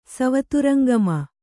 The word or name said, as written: ಸವತುರಂಗಮ